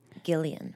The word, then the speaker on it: Gillian: But you mentioned Jillian Robespierre before, and I said her name correct, right? – Jillian it's a, Robespierre. actually a hard G. Gillian.